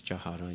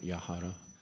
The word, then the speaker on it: jahara: Jahara prasadi bai, this is jahara, not yahara, or jaha hoiti, anyway.